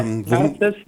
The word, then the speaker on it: Narziss: Du hast jetzt ja auch noch gerade eben einen neuen eigenen Film fertiggestellt, Narziss spricht man, glaube ich, aus, oder? Oder Narziss? – Mhm. Ja, Narziss, so wie die Narzisse.